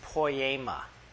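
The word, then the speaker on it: poiema: The word for workmanship is poema. Literally, it sounds like the word poem. It is. It's the word for poem.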